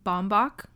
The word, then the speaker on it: Baumbach: Baumbach.